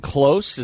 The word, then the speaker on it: Klose: Norwich defender Tim Close. Is that how you say it, Mellor? – Spell it. – K-L-O-S-E? – Yes.